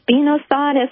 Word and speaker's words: spinosad: And I have a question about diatomaceous earth versus spinosad, S-P-I-N-O-S-A-D.